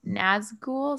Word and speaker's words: Nazgul: The Nazgul, is that how you say it? – Yeah.